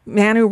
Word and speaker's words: Manu: Manu Raju of CNN. I said Manu. – You put the accent on the wrong syllable.